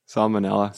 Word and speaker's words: salmonella: He said, "I think I have salmonella." Wait, how do you say it? – Salmonella.